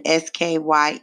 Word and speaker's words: Skye: That's for rain, and then S K Y E, sky.